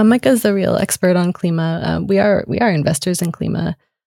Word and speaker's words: Klima: K-L-I-M-A. – And Klima. – Klima. – I think it's pronounced Klima, yeah. – I believe you. Klima, K-L-I-M-A.